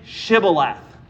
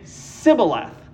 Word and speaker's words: Shibboleth: And he would say Sibboleth. So not so Sibboleth, not sh- like a shoe, Shibboleth. He could only say Sibboleth. He could not pronounce the sh sound in Shibboleth.